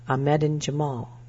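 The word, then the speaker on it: Ahmedin Jemal: The American Cancer Society recommends that all women start at age 25, says Ahmedin Jamal. That's spelled A-H-M-E-D-I-N. The last name is J-E-M-A-L.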